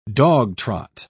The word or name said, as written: dogtrot